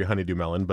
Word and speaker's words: honeydew: But I used to call it honey duty melon when I was a kid. That was I didn't know how to say it correctly. And it was honey duty.